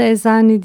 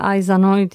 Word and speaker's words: Aizanoi: Adı Ezani diye değil de genelde Ayzanoy diye okunuyor. Ee, dünyanın ilk e, stadyum tiyatro kompleksi, dünyanın ilk borsa yapısı var burada.